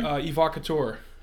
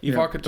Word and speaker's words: Evocateur: Evocateur. Am I saying that correctly? – Yep. – Evocateur.